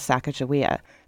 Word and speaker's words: Sacagawea: I want to just note that there are multiple spellings and pronunciations of Sacagawea, since her name was chronicled by white non indigenous people in multiple spellings and pronunciations, but I'm using the pronunciation favored by the Lemhi Shoshone.